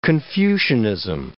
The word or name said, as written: Confucianism